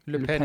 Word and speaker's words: Le Pen: Le Pen.